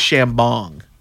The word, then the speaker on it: Chambong: I believe it's pronounced Chambong. – Like champagne?